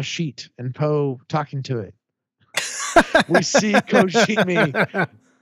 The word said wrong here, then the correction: Kijimi: You still said it wrong. It's Kijimi. There's no O in it.